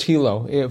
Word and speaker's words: helo: No, it's pronounced Hilo for helicopter. – Really? H-E-L-O? Not H-E-L-I-O? – No, it's too complicated.